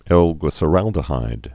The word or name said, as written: L-glyceraldehyde